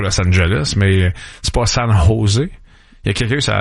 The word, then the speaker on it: San Jose: Puis un ou deux, au lieu de dire San Jose, qui est la prononciation parfaite, un ou deux aurait dit San Jose.